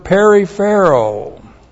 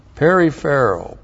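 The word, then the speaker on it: Periphero (περιφέρω): We start out with the verb periphero. Looks like this in the Greek. Periphero, P-E-R-I-P-H-E-R, long O.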